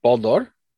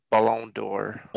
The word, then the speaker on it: Ballon d'Or: I don't know how it's pronounced. – Ballon d'Or. – Ballon d'Or. – Ballon d'Or. It's golden ball in French.